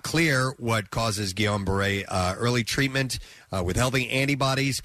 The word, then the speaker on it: Guillain-Barré: She said in the video, I'm dealing with something called Guillain-Barré syndrome. – Guillain-Barré, yeah. – Guillain-Barré, okay.